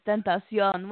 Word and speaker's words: XXXtentacion: The way you pronounce it is XXX, and then it's Tentacion. Which Tentacion, if you don't know, for the listeners out there, is temptation in Spanish.